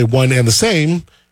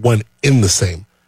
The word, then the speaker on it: one and the same: By the way, one and the same is the right way to say it. It's not one in the same.